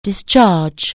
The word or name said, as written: discharge